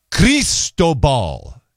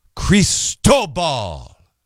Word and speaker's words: Cristobal: Cristobal is how they want you to pronounce it. Crease, so they're going K-R-E-E-S with an emphasis on the middle syllable. Cristobal. It's Tobal. Cristobal. Edward.